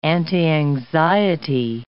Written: antianxiety

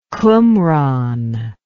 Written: qumran

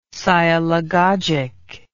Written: sialagogic

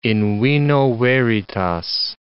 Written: in vino veritas

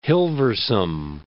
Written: Hilversum

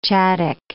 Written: Chadic

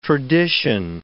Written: tradition

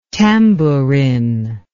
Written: tambourin

